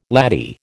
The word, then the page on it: laddie: American English